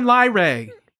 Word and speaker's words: Lyrae: It's Omicron Lyrae. Omicron, L-Y-R-A-E.